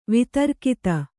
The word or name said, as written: ವೇತ್ರಗೀತ